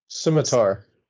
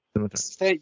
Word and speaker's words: scimitar: Scamander, however you pronounce it. – Scimitar. – Scimitar.